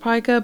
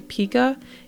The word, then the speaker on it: pica: Pica, and it is pronounced pica, but you may hear people say pica, is a disorder where someone has a compulsion or craving to eat items of no nutritional value.